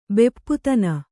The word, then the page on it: ಬೆಪ್ಪುತನ: Kannada